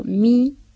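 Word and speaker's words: mis: Y otro grupo termina el participio pasado en S, que no se pronuncia. Por ejemplo, mi y pri.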